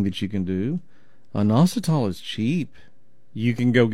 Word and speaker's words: inositol: It's called inositol. Let me spell it for you. I-N-O-S-I-T-O-L.